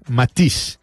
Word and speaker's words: Matisse: Uno que no es chavo ni conoce toda esta situación, Matiz, es que se escribe Matisse, ¿eh?